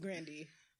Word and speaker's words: Grande: Or Grandy, however you say her name. – Yeah, Ariana Grande. We are not calling her Grande anymore. That is not her name. – I like Grande. – It's Grandy.